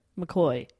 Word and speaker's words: McCaughey: Also, his surname is spelt M-C-C-A-U-G-H-E-Y. – Yep. – McCoy.